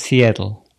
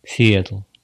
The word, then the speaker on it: Сиэтл: Есть у нас такой город Сиэтл. Сиэтл. Они все произносят это «э» ближе к «а». Сиэтл, они любят говорить, местные жители.